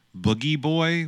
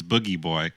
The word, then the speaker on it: Booji: Mark Mothersbaugh loved to come up with characters such as Boogie Boy, which is spelled B-O-O-J-I, like, you know, Bougie Boy or something, but it's Boogie Boy.